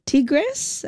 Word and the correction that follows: Tigre: it takes you... – Oh, Tigre, Tigre. Tigre.